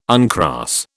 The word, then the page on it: uncross: British English